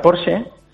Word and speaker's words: Porsche: En alemán se pronuncia Porsche y luego pues hay mucha gente que como tenemos la tendencia a todo pronunciarlo en inglés, pues dice Porsche. Pero originalmente, como lo diría el señor Ferdinand Porsche, pues es en alemán y es Porsche.